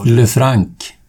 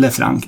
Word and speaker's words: Lefranc: Eh, ja, hur uttalar man efternamnet? Ja. – Lefranc. – Ja, på svenska. Lefranc. – Ja. – Lefranc. – Kan inte franska. Nej, inte jag heller. Nej. Lefranc. Norbert Lefranc. Ja, det är den ja.